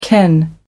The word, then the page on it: ken: American English